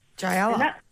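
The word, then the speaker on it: Jyelah: So I want to prop up Jayala. This is a skincare and artisan soapery business based actually on Rajasthan. – How do we spell that? – Yep, it's J-Y-E-L-A-H. – Yep, Jayala.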